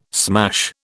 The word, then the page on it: smash: British English